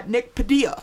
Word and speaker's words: Nick Padilla: And then super special guest, so happy to have him on, Nick Padilla.